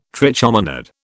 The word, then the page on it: trichomonad: British English